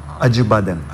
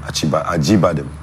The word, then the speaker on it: Acıbadem: Is it Acıbadem? How you pronounce it? The hospital. – Acıbadem. – Acıbadem. Yeah. Acıbadem. Big shout out to them for,